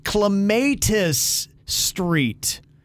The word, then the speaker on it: Clematis: The main street in downtown West Palm Beach is supposed to be pronounced Clematis Street.